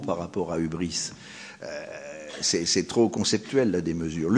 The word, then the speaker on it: hubris: Alors j'ai écrit ici aussi hubris, on prononce hubris, on peut l'écrire hubris.